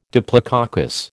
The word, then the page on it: diplococcus: American English